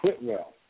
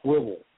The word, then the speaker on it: Whitwell: It's spelled W-H-I-T-W-E-E-L-L. Looks like yeah. – Whitwell. It's pronounced, it's just pretty much pronounced Whibble. – Yeah. – And if you say anything but Whibble, then they know you're not from around here.